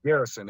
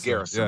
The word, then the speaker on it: Garyson: So there's Gary's son, but it's pronounced Garrison. If you say it fast, Garrison. It's Garrison. – Yeah, yeah, yeah. – Yeah. – All right. – But it's on paper, it's spelled out Gary's son, G-A-R-Y-S-O-N, but it's all one word.